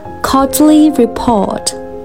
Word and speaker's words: quarterly report: Quarterly report，也就是季报和季度报告的意思。那刚才的这个读音呢是英式的发音，大家可以注意到，all、 paw 都是没有 r 的音的。如果我们是美式发音的话，就要注意把 r 的音带进去，变成 quarterly report。